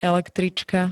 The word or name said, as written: električka